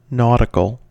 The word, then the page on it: nautical: American English